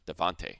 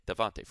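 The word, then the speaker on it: Devonta: Not to mention Tevin Coleman has been fantastic after the injury to Deontay Freeman. I said his name wrong. Devontae. There we go. Not Deontay. Devontae Freeman.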